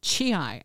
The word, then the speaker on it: chi: If you do any research with the Asian martial arts and you watch them with their sound, chi, I believe is the way it's pronounced,